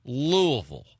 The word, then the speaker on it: Louisville: If you go by what the correct pronunciation is for people that are residents or natives of Louisville, they will tell you that it's Louisville.